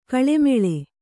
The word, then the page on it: ಗಾಳುಮೇಳು: kannada